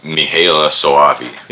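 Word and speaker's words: Michele Soavi: Well, it kind of looked like, other than the Luigi Cozzi, it mostly stayed between Lamberto Bava and how do you say his name? Michele Sova? – Mihaela Soavi. – Yeah, I always say it wrong. I'm never going to be able to say it right.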